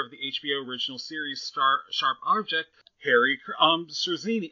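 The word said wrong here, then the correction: Czerny: Once again, I don't know how to... I should have really bothered to figure out how to pronounce people's weird names. – Henry Zerny. – Yeah. Thank you.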